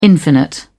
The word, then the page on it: infinite: British English